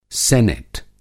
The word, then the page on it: सिनेट: Hindi